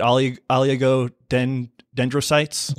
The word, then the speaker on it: oligodendrocytes: uh, how do you say it oligodendrocytes? – Yep.